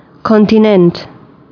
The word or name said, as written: Kontinent